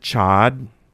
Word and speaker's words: chaud: Because isn't there a French word, chaud, it means hot? – Chaud. – Chaud, okay. – The French pronounced chaud. – But it's spelled C-H-A-U-D? – C-H-A-U-D, yes. And if it's a feminine, then it's chaud. – Chaud. – And then you pronounce the D because there's an E that comes after.